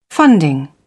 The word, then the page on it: funding: British English